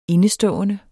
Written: INDESTOD

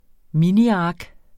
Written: miniark

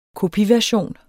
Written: kopiversion

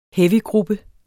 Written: heavygruppe

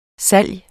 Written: salgs

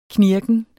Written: knirken

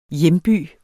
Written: hjemby

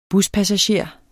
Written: buspassager

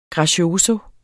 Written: grazioso

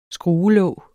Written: skruelåg

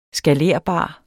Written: skalerbar

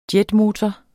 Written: jetmotor

